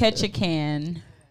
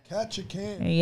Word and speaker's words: Ketchikan: Ketchikan. – Ketchikan.